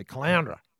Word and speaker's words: Caloundra: So you know how to pronounce Caloundra. – Well, just like you just said it, Caloundra.